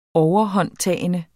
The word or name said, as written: overhåndtagende